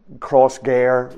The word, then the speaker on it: Crossgar: Crossgar rhyming with fair, according to the reports of the man that spoke.